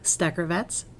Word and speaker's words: Steckervetz: It's Lori Steckervitz, S-T-E-C-K-E-R-V-E-T-Z.